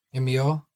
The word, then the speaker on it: Emile: Emile.